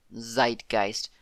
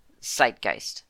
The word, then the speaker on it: Zeitgeist: So what I'm taking away from this is that it is supposed to just be, rather than a Z, Zeitgeist, I think it's supposed to be more like an S. That's what I'm taking from this, a hard S. So Tsunami, Zeitgeist, Susan, yes.